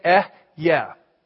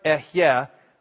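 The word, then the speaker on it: Eh, yeah (אהיה): Everybody say, just two syllables, everybody say, "Eh, yeah." "Eh, yeah" means I am. "Eh, yeah, asher, eh, yeah."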